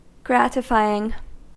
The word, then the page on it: gratifying: American English